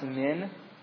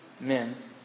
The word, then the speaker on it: min: There's a little word, a two-letter word in Hebrew that's pronounced min, M as in Matt, I-N as in Nathan, min.